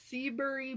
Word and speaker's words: Seabury: S-E-A-B-U-R-Y. – I love that. – Or Seabury. Seabury or Seabury? – I kind of like Seabury. – Seabury.